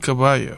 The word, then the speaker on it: Caballo: Humboldt Caballo.